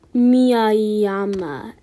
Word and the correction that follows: Miyajima: That's Tatsuo. Here, let me go slower. Tatsuo. And now for his last name. Miyajima.